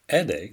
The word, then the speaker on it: aider: Aider starts with the English sound of the letter E in the word AND. The second part, DE, sounds like the word E, which we have seen before, with the letter D in front of it.